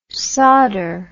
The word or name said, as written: sawder